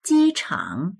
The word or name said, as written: ji1chang3